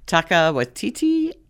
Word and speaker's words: Waititi: Taika Waititi and Gugu Mbatha-Raw.